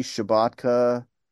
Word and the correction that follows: Shabaka: Henley? – Yes, that's a good scene. Yeah, Barry Shabaka Henley.